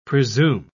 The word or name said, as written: presume